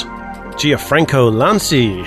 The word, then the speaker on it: Gianfranco Lanci: Vista is a dud, according to Acer's boss, Gianfranco Lanci. Hey, how about that? I think I pronounced it sort of almost close. – I think that was an excellent attempt.